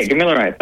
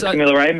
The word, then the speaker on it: Gamilaray: Yeah, Gamilaray. Perfect. Yep. So it's Gamilaray.